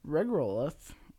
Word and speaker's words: regolith: The technique unlocks the high amounts of oxygen in regolith.